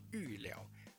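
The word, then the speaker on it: 尉缭: 总之，我们先了解有这样的一个人，还有另外一个人叫做玉辽。这个玉呢，就是门神尉迟恭的那个玉。那如果写成一般的字，我们把它念成尉，就是太尉、校尉一个官职的那个尉。那辽呢，就是一个密字旁，然后在一个辽波去掉手字旁的右边那个部分，啊，这个它叫玉辽。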